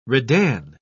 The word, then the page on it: redan: British English